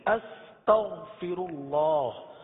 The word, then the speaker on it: استغفر اللہ: شاید آپ کو یاد ہو کہ ہم نے تلفظ کی درستی کے حوالے سے ایک کلمہ جس کی ادائیگی کی تھی، استغفر اللہ، جس میں غین کی ادائیگی کو درست کیا تھا. اس کو دوبارہ پڑھ لیجئے، استغفر اللہ استغفر اللہ استغفر اللہ.